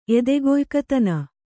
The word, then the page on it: ಎದೆಗೊಯ್ಕತನ: kannada